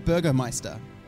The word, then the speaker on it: Burgermeister: Is that Burgermeister? – Burgermeister. Isn't that how you pronounce it? – As in the master of burgers?